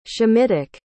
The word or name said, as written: shemitic